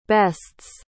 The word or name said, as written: bests